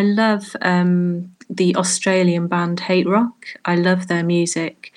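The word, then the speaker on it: HTRK: Um, so it's a bit electronica, a bit dark, brooding, but I love everything Hate Rock do. It's H T R K, but you say you pronounce it Hate Rock.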